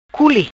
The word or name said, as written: Culi